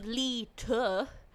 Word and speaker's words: Leta: It's my name phonetically, because we're not really great Italians. So Vicky Leeta on the Instagrapes and the Twitter.